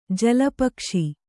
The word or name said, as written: ಜಲಪಕ್ಷಿ